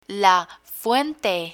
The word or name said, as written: la fuente